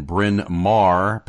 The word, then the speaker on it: Bryn Mawr: Bryn Mawr, Pennsylvania.